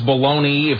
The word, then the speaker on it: Bologna: Why is it pronounced baloney if it's spelled Bologna?